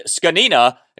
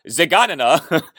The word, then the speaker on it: Zgonina: Uh, the last name is pronounced Skanina, not Zaganina. okay. Now, it's spelled Zaganina, but it is pronounced Skanina. Uh, the name is spelled Z G O N I N-A.